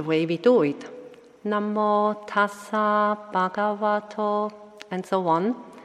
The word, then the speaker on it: Tassa: And when he had finished the three repetitions, I just started to translate it into the Burmese way of pronouncing the Pali, which is Namo Tata Bhagavato Arahato Tamma Tambo Tata.